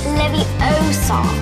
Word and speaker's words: Leviosa: You're saying it wrong. It's Leviosa.